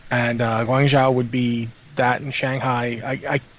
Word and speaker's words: Guangzhou: 6606 in the chat channel says pronounce it Guangzhou, apparently, because they built a hospital there. So that's good. Guangzhou.